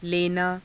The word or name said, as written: lenaa